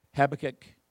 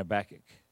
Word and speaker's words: Habakkuk: But if you have a weapon, go with me to two definitions, or two pronunciations, rather, Habakkuk and Habakkuk.